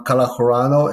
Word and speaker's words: Calahorno: Uh, um, you can reach me in uh, my uh, LinkedIn page, Juan Calahorano, and uh, last name is spelled C A L A H O R N O, or also JLC and Associates.